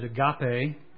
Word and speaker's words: agape: And the kind of love that he uses here is the Greek word agape. You may pronounce it agape.